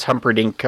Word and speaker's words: Humperdinck: Prince Humperdinck. – Prince Humperdinck, which I almost mispronounced that.